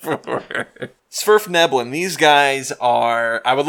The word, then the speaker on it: Svirfneblin: It's Svirfneblin. That's how you say the name.